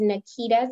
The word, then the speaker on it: Nakita: My name is Nakita, spelled with an A, Hemingway with one M.